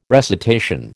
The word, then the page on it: recitation: American English